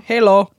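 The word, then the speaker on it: hallo: Halo. It's H-A-L-L-O.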